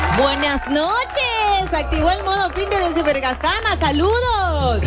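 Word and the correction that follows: Ivirgarzama: Era Ibirgazama, ¿no ves? Sí. Lo dije mal, ahora sí, Ibirgazama.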